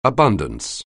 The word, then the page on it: abundance: anglais